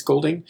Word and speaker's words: Golding: And it's Henry Golding, I believe. – Yep. – If I said his name correctly, I think it's Golding.